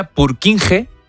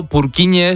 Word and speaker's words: Purkinje: Es que se escribe, en español sería Purkinje. Pero Purkinje es en checo, que es por porque es el apellido del descubridor.